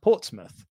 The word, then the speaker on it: Portsmouth: So it's like, so Muth was, when Portsmouth was established, that was the way to say mouth was Muth. So it's pronounced Portsmouth, but it's spelt Portsmouth.